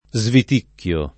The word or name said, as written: sviticchiare